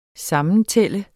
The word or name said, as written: SAMMENTALT